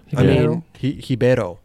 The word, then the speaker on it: Ribeiro: Ribeiro. That's how you say it in Portuguese.